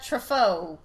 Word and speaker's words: Truffaut: I judge you for your love of that Truffaut.